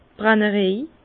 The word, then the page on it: d' Brännerej: Bas Rhin; Herrlisheim